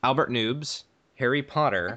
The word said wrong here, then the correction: Nobbs: I think that's pronounced Nobbs.